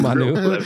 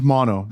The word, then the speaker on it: Mano: He's Manu. A lot of people call me Manu, like a menu. No, it's mono, but yeah. – Isn't it Mano? Mano? – Mono. – M-A-N-O.